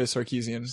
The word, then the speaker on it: Sarkeesian: with Anita Sarkeesian? Might have said that wrong. – Anita Sarkeesian. – So, yes.